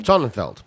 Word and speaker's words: Sonnenfeld: And it felt very much like the Sommerfeld kind of what's his name? Is it Sommerfeld? – Sonnenfeld. – Sonnenfeld. I always get his name wrong.